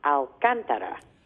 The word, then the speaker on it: Alcantara: That's very close. It's actually a city in Spain pronounced Alcantara.